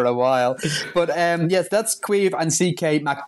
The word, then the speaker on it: Caimh: We should just tell our listeners that Quive is spelled C-A-I-M-H.